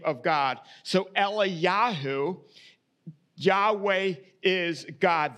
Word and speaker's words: Eliyahu: Actually, in Hebrew, it's probably more along the lines of Eliyahu is how Elijah's name would be pronounced.